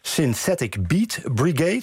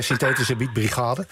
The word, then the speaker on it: Synthetic Beat Brigade: Bekend als gitarist van Kane, maar ook onderdeel van het collectief Synthetic Beat Brigade, zeg ik het goed zo? – Ja. – Of moet ik zeggen Synthetische Beat Brigade? – Ja, van mij. Voor mij mag het allebei.